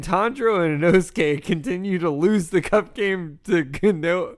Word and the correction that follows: Kanao: How do you say your name? Kanoe? – Oh, wow. We had it earlier. Kanao. – Kanao. – Kanao? I don't know. – Kanao, yeah.